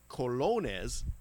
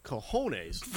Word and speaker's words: colones: So apparently, it's pronounced colones. I pronounced cojones.